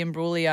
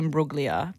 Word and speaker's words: Imbruglia: This is because on the Triple J socials, I went to go say Torn, Natalie Imbruglia, and I said Natalie Imbruglia, because I pronounced the G when the G is actually silent.